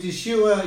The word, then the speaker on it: Yeshua: Brothers and sisters, we use Yeshua. Yeshua is how you say Jesus in Hebrew.